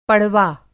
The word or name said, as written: पड़वा